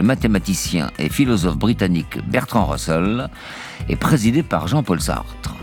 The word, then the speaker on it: Russell: Le tribunal Russell, on prononcera Russell par commodité, entend ainsi faire œuvre d'information et d'éducation, comptant sur le retentissement médiatique de ce procès, qui conclura à la culpabilité des États-Unis.